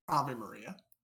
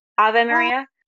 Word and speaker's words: Ave Maria: So he performed to is it is it Ava Maria or you know I'm talking about? Ave Maria, I believe. – How do you say it? – Ave Maria. – Ave Maria.